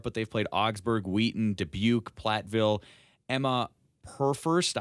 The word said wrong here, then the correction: Perfest: I hope I'm pronouncing that correctly, first-year head coach who's been with the program now for a number of years. Perfest, excuse me. It's Emma Perfest.